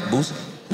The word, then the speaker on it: Boosie: And there was one time you had Boosie on your show. – Oh, shit. – And you so eloquently asked Boosie. Am I saying his name right, Boosie? – Yeah. – I said it right? – Yeah.